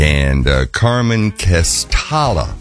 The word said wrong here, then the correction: Castaldi: How do you pronounce that? Castaldi. – Yeah, Castaldi. – Castaldi is on drums.